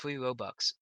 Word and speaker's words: Robux: Being a 13-year-old, I spent like $50 on Robux. Is that how you pronounce it? I can't pronounce it. – Yeah, Robux.